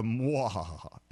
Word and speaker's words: muhahaha: It has to be a mwahaha. It's spelled M-U-H-A-H-A-H-A.